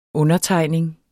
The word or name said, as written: undertegning